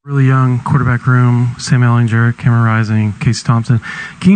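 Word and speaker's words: Ehlinger: I feel like the two older guys, Ellinger, with the hard G. – Good morning, Mad Radio. That was Tom Herman as part of Big 12 Media Days yesterday. So remember, Ellinger with the hard G.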